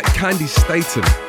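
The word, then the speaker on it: Candi Staton: But before we play you the next one, I'm just about getting over the fact that I've been mispronouncing Candy Staten's name my entire life. Candy Staten.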